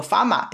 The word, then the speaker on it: FAMA: 叫那个农夫 farmer，就是本本身呃农夫的英文应该是 farmer 嘛，就有的两个 r 辣辣里向头，但是因为香港人发音个辰光会得拿搿只 r 省略掉，所以伊拉个名字叫 farmer f a m a，就老香港人个发音个搿只搿只方式。